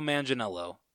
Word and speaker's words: Manganiello: Really quickly, I want to make sure that we're pronouncing his name right. – Yeah, his is a tough one. – Yeah, Joe Maginello. Is that how you say it? – However you say it. – Yeah, I'm going with Joe Manganiello.